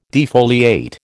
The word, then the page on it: defoliate: American English